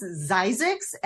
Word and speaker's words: Zzyzx: And Warren was wondering, if the California place name is Zizix, Z-Y-Z-Z-X? Is that? – Per Wikipedia, it is pronounced Zizix, and yes, that is correct. Z-Z-Y-Z-X.